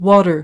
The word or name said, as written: water